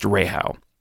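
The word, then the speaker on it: REHAU: And personally, I think the most difficult thing about this is learning how to say Rahow, because it's just a capital R, capital E, capital H, capital A, capital U, but it's pronounced Rahow.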